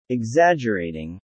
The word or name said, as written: exaggerating